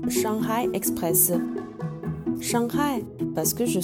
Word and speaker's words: Shanghai: Et vous l'avez peut-être remarqué, on prononce la lettre H en Chine, donc Shanghai est la bonne prononciation.